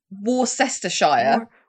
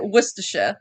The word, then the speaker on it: Worcestershire: You know, like Worcestershire is the worst English word because it's like Worcestershire. Is how it's spelled. – Worcestershire. Yeah, but you say it. – Yeah, but you say it Worcestershire.